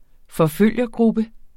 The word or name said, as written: forfølgergruppe